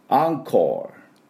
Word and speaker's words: encore: で、スペルが ENCORE ですね。普通だったら EN だから N って読みそうなところなんですけど、これでアンって読みまして、アンコール。